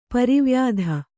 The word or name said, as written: ಪರಿವ್ಯಾಧ